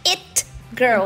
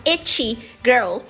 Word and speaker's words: it girl: Cuidado para falar it girl, uhum, e não itchy girl, porque seria a menina que coça.